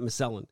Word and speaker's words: Miscellen: I'm first on this block with a band, and they are from Washington, D.C., called Miscellen, M-I-S-C-E-L-L-E-N. I always think of it when I read it, and I think my brain thinks mescaline, but it's not how it's spelled. It's like Miscellen.